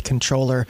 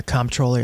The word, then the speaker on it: Comptroller: He is the Libertarian candidate for New York State Comptroller, if you pronounce it like him, or Comptroller, if you pronounce it like me.